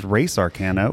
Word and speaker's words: Res Arcana: We are going to be reviewing Tom Lehman's Res Arcana, or as I've learned this week, it's pronounced Race Arcana, which Wraith I Arcana. Think is actually quite relevant to the game itself. – Why is it pronounced that? – Because it's a reference to Race for the Galaxy, which the game is a sort of a 2.0 of.